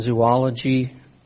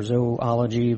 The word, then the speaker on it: zoology: We get the English words zoo and zoology or zoology would be proper pronunciation, from the root word of this.